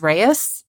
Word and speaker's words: Reis: It's R-E-I-S, which I just thought was Reis, but it's Reis. So. – Oh. – Because of course it is. And if I had thought it was Reis, it would have been Reis.